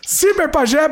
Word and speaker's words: Ciberpajé: Cyber falei errado de novo o nome. Cyber Pajé, brigadão.